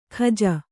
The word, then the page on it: ಖಜ: Kannada